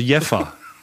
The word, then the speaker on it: Jever: Und jetzt wurde mir geschrieben, nein, Jever wird tatsächlich nicht Jever ausgesprochen, sondern das V wird wie ein F gesprochen, also Jeffer.